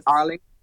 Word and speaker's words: Harlingen: What, Harlingen? – Harlingen, Harlingen.